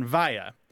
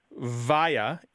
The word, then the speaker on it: via: Thing is, there are two standard pronunciations of this word, and they overlap greatly, and it's not altogether clear to anyone which one is the most correct at all times. Throughout the English-speaking world, people say both via and via. There is a general kind of separation of the two pronunciations. You probably say via if you're using the preposition.